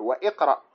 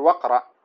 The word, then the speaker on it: واقرأ: فمثلا بعض الناس يقول وإقرأ، هذا غلط، تقول وقرأ ولا تقول إيش وإقرأ، حتى في الكلام العادي، هذا ليس خاصا بالقرآن، هذا في أصل اللغة العربية.